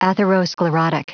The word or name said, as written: atherosclerotic